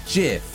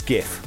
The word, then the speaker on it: GIF: Is it pronounced GIF or JIF? – Yeah, it is GIF. – Yeah. – Yeah, GIF. We all know that.